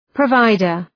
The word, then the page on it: provider: prə’vaıdər